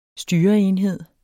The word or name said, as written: styreenhed